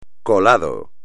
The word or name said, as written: colado